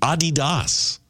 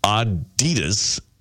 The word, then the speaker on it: Adidas: And we've been pronouncing Adidas wrong. It's supposed to be Adidas. – With a slight emphasis on the first syllable, not the second. Not Adidas, stressing the second. Adidas, because it's actually a guy's name.